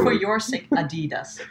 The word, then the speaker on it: Adidas: Adidas. – Adidas. – You gotta say it like American. – No, it's not Adidas. – American. Adidas. Is German-based. Herzogenaurach. It's called Adidas.